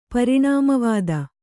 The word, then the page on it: ಪರಿಣಾಮವಾದ: pariṇāma vāda; Kannada